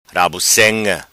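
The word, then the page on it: Rabucenga: patua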